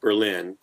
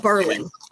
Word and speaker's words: Berlin: It was set up in a town in Massachusetts called Berlin, which is spelt like Berlin. – But Berlin. – Berlin. And I live in New Hampshire.